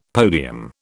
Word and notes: podium: British English

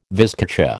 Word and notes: vizcacha: American English

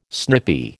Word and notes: snippy: American English

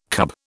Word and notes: cub: British English

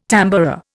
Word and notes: tamboura: Indian

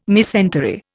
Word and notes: mesentery: Indian

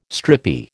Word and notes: stripy: American English

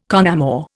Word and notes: con amore: Indian

